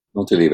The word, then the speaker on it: Montalivet: Like, because the workshop, in case people who are listening don't know this, the workshop is at, and I'm going to mispronounce it, Montelive? – Montelive, that's fine. That's good.